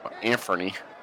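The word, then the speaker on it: Anfernee: Got this little dude trying to give a chair to the Sandman with his Anthony Hathaway jersey. Anferny. – I used to say it wrong all the time, too.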